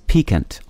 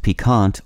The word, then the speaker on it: piquant: The word of the day for April 16th is piquant, also pronounced piquant or piquant. Piquant is spelled P-I-Q-U-A-N-T.